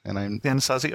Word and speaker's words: Anasazi: For the longest time, they were known, I believe you pronounce it the Anasazi. – Anasazi, okay.